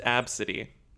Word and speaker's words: ABCDE: Yeah, pronounced Ab City. Ab City.